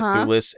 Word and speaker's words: Thewlis: So David Thewlis, and I believe that's how you say it, Thewlis as Ares.